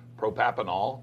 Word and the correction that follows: Propranolol: Propranolol, yeah.